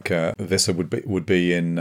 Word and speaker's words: Wissa: that it is pronounced as a W, and it's a V for Valtamada. So yeah, we need to try. – So that's Valtamada and Wissa.